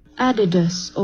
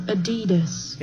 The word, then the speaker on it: Adidas: Adidas or Adidas.